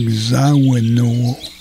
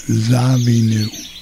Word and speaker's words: Zawinul: That's how I learned that Joe, it looks like Zawinul, is actually pronounced Zawinul.